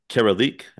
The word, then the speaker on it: Caralique: It's already out and you're already winning awards from what I hear left and right for your role in the film Caralique.